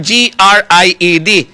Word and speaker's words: grid: Hindi blada pariyos ang pronunciation sang GREED. Kag GRIED, grid. Ha?